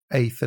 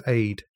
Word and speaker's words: Aith: So the camp is divided, the town is divided as to who pronounces it Aith and who pronounces it Aid. – Wow. – But I believe that many locals will refer to it as Aid rather than Aith.